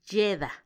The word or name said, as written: yeda